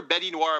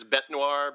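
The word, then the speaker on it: Bête Noire: And and you called her Betty Noir, but I'm pretty sure it's Bette Noir, because that becomes a pun, right? It's Black Beast in French.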